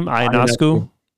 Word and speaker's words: Ionescu: Sabrina, how do you pronounce her last name? Ionescu? – Yeah.